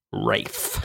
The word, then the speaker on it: Ralph: Mr. Fiennes, it says Ralph here. – Yeah. – Yeah. It's pronounced Rafe.